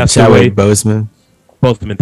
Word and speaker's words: Chadwick Boseman: We had Chad Boswick from, I hope I said his name right, from Black Panther. – Chadwick Boseman, thank you.